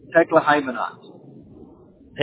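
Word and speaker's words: Tekle Haimanot: Tekla Hymenot.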